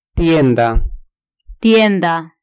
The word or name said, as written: tienda